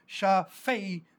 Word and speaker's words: Shaphel: So we see that, and so we have these two words then in the Old Testament, Shaphel, S-H-A-P-H-E-L, long E, Shaphel, as translated in the Greek, tapainao, which means humility, and we saw it there as the verb form.